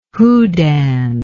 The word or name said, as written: houdan